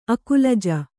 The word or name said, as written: ಅಕ್ಕುಲಜ